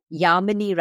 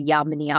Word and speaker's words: Yamini: Yamini rhymes with harmony. So Yamini Harmony.